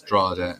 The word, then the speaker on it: Drogheda: But in Louth, there are two towns, Drogheda and Dundalk, and we hate each other. – And Drogheda is the one with silent G in the middle.